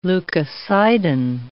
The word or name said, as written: leucocidin